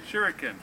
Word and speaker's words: Shuriken: The double attack Shuriken. – Shuriken. Three syllables.